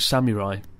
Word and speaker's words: samurai: and he kind of pointed out that I kept mispronouncing samurai, because it's not samurai, it's samurai, isn't it? – Samurai.